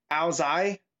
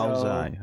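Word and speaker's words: Áo dài: But it's pronounced apparently Ao Dai, and it's Vietnamese. So, Ao Dai, okay.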